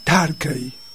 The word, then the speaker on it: Turkey: Se escribe Turkey, Turkey, y se pronuncia Tarkey.